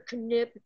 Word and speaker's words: Knipp: Mr. Knipp, you listening to me? I'm still mad at you. never trust. – Let it go. – Pronounce the K when their last name is spelled K-N-I-P-P.